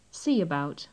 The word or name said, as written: see about